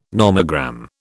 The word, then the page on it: nomogram: British English